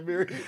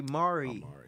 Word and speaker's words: Amiri: I just heard the rapper say Amiri jeans. And no. It's a clothing line. – I've heard somebody say Mari. – They said it wrong, too.